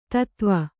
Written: ಧತ್ವ

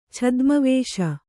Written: ಛದ್ಮವೇಷ

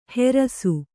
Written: ಹೆರಸು

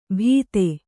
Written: ಭೀತೆ

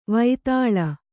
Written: ವಿತಾಳ

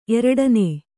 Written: ಎರಡನೆ

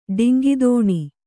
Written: ಡೊಂಕುತನ

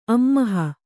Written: ಅಮ್ಮಹ

